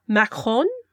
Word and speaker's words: Macron: Macron, I think is how you say it.